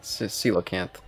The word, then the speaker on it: coelacanth: Coelacanth? – Coelacanth. – Coelacanth? – Coelacanth, yes.